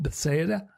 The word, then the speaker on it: Bethsaida: A man at the pool of, how do you say it? – Bethsaida. – Bethsaida, whatever.